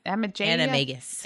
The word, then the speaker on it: Animagus: Animagus.